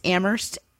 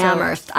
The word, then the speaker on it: Amherst: It's Amherst, Massachusetts. The H is silent. – So. Amherst.